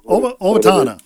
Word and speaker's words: Owatonna: I have a problem pronouncing that one, but Owatonna, I believe it's. – Owatonna. – Yeah, there we go.